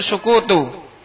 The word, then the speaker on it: sukutu: Bacanya itu sukutu.